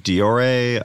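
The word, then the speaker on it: Dior: So the new story, if you saw this on Twitter, I believe it's pronounced Diore, like fashion company, released like a new fragrance line.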